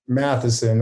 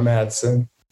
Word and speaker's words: Matheson: Matheson, not Madsen.